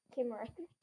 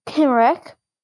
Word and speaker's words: Cymric: The Cymric, pronounced Cymric. – Cymric.